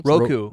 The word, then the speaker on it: Roku: Is that how you pronounce Roku.